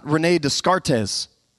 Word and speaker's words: René Descartes: And whenever you see his name, you might say it wrong, but it's René Descartes, not René Descartes.